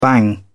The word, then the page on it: bang: British English